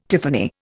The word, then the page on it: tiffany: Indian